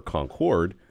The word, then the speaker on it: Concorde: You know, the company is 28 years old, and and now here's the difference: um, the city is pronounced Concord. – Concord. – I learned that. That's right. Whereas we're Concorde, like the big airplane, like you know.